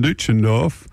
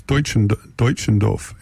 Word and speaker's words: Deutschendorf: So let's just play a little Jonathan Duchendorf. Bob Middleton tells me how to pronounce it. It's Deutschendorf.